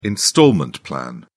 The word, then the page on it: installment plan: British English